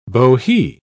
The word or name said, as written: bohea